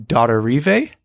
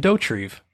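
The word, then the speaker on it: Dauterive: I thought it was Jim Dottorive, but I was watching the behind the scenes for this episode as I was preparing for our episode this week, and he pronounces it Jim Dottorive. So we were way, way off.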